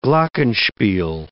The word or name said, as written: glockenspiel